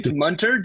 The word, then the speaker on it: Munter: It's Munter, just like Hunter.